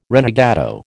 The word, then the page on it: renegado: American English